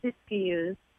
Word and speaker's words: Siskiyous: Siskiyous, and that is spelled S-I-S-K-I-Y-O-U-S. In Weed, California, and Weed is W-E-E-D, just like the thing that grows in your garden.